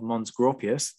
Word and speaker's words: Mons Graupius: The Romans in Scotland, the Battle of Mons Graupius. There we go. I've learned to pronounce it properly now.